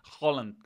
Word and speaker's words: Haaland: Holland, como dice Marion, que sabe pronunciar.